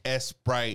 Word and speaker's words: Sprite: It's like, pronounced Esprite. – Esprite. – Esprite in Mexico.